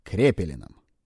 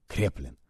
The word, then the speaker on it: Крепелин: У нас есть классификация безумия, созданная Крепелином. На самом деле его фамилия произносится как Креплин.